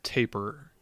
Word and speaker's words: tapir: I think it's tapir. – Taper.